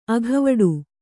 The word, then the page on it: ಅಗವಡು: kannada